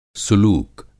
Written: सुलूक

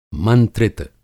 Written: मंत्रित

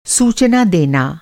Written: सूचना

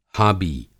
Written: हाबी

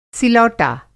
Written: सिलौटा

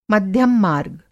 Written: मध्यम-मार्ग

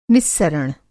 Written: निस्सरण

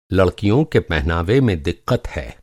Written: पहनावा

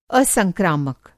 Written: असंक्रामक

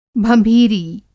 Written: भँभीरी

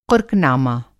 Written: क़ुर्क़-नामा

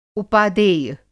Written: उपादेय

